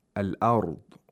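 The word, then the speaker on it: al-ard (الأرض): On the contrary, in the word al-ard, or the phrase wal-ard, the lam sakina is pronounced because there is a moon letter after the lam sakina.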